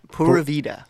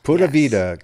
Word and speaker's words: Pura Vida: And it's Pura Vida. Did I pronounce that correctly? – You got it. Pura Vida. – Pura Vida, community singing groups.